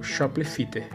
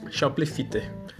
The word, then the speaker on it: Shoplifter: Que é o Shoplifter. Que é como tá. Eu tentei separar as sílabas como eu pronuncio, né? Pronunciava e pronuncio e vou continuar pronunciando. Porque eu acho legal. Shoplifter.